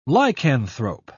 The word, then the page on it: lycanthrope: British English